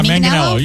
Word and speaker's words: Manganiello: Joe Manganiello. Is Manganiello? – Yeah, Manganiello. You're saying it right.